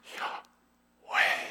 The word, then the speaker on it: Yahweh: A couple weeks ago, Rob shared the name of God, Yahweh, YHWH, pronounced Yahweh, the breath of God.